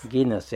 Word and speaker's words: Guinness: Guinness, ja.